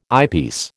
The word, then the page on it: eyepiece: American English